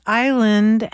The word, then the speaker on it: island: Why does island have an S in it, and why is it pronounced with a long I?